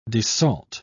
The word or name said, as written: desalt